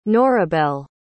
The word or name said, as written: norabel